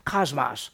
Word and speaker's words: cosmos: It's K-O-S-M-O-S in English. It's not cosmos, it's cosmos. The Omicron in Greek has an A-type sound, Omicron, where the omega is the plural, I mean is the long vowel. This is the short vowel. So it's pronounced cosmos, and we get cosmetology from it, because cosmos means ordered system.